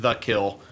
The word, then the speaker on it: Kiln: We're right on, so we're in The Kill, and you say it like The Kill, but it's spelled K-I-L-N.